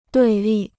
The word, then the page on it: 对立: duì lì